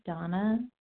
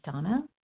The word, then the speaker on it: Dana: This is Donna B. calling from Denver, Colorado. I do spell my name D-A-N-A, although it's pronounced Donna.